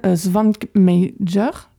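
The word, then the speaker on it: Švankmajer: qui est donc Jan Svankmajer. Ouais, je l'ai très mal prononcé, mais... – C'est ça, effectivement.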